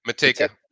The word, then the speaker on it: Manteca: Manteca.